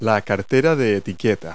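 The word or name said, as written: evening bag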